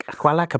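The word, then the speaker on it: Qalaq: Their new album is Kyalak. I mean, it's Q-A-L-A-Q, Kyalak? Kyalak? I don't know. – Kyalak.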